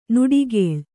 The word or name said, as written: ನುಡಿಗೇಳ್